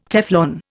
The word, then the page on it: Teflon: Indian